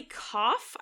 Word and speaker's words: Keough: Um, Riley Keough, I believe is how you pronounce her name. It's K E O U G H.